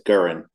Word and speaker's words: Gurin: It's Gurin. – Okay, Gurin. It's just how it looks, man.